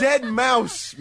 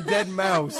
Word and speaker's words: deadmau5: It's Dead Mouse, man. Dead Mouse. – Oh, man. – All right, hold on a second. It's spelled DEADMAU5.